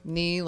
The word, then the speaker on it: Nee: Nee, like your knee? – Well, pronounced like that, yes, but without the K.